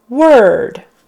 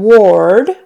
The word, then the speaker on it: word: Instead of saying ward with an OR, we say it as if it were word. Word. Ward is not how we say it. Word. Even though it's spelled OR, it's word.